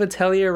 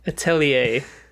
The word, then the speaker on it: Atelier: Isn't it like a French pronunciation? – Atelier. – Atelier.